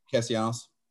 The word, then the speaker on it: Castellanos: Another Manfred thing, Nick Castellanos, or how do you say his last name or whatever? – Castellanos.